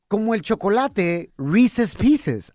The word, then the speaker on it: Reese's: Reese's.